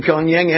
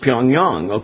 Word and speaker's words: Pyongyang: They refuse to pronounce it correctly. They keep saying Pyongyang, and I think a lot of us, you know, have been exposed to the wrong pronunciation.